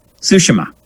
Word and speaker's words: Tsushima: All that was fixed this week when Sucker Punch dropped an 18-minute guided tour of Ghost of, and now I've learned how to say it, I believe, Ghost of Tsushima, I believe is how they were saying it in the trailer.